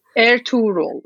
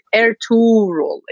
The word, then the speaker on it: Ertuğrul: It's like in Turkish alphabet, we have a G, we have, it's like a French with a sign, like, you know, different E's. G is the same, so we call it Ertuğrul. It's like, it's a soft G.